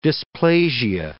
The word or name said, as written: dysplasia